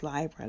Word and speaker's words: Libra: And the sun is there now, not in Libra, which is, those who listen to me know it's actually pronounced Libra, like library in books.